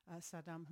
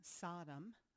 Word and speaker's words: Saddam: Bush, around the time of 9/11, would use, he would first off mispronounce Saddam Hussein's name to call him Sodom, as in sodomy, I guess.